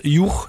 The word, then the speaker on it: Hugh: Auch im Englischen, wie spricht sich das aus? Juch, ne? H-U-G-H geschrieben, alter Vorname oder überhaupt noch moderner Vorname.